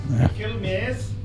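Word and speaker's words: Quilmes: No, but is that how you say it? Kilmes. Kilmes. Sam has corrected us and taught us that it's Kilmes. Sam knows all. – Oh, so the Q's like a cake kind of vibe.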